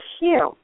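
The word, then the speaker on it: HU: And when we put our attention here with our eyes closed, we're going to chant the word Hue, which is spelled H-U, and it simply sounds like I just said, except it's drawn out.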